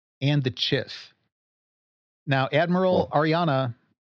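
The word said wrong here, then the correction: Ar'alani: Arlani? – Arlani.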